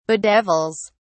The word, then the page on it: bedevils: British English